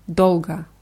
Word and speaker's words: долго: Dolga wird mit zwei O's geschrieben, in der Mitte und am Ende. Und das letzte O wird eher wie A ausgesprochen. Dolga.